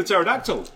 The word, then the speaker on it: pterodactyl: It's like the P in the pterodactyl. – Yeah, of course, it's silent.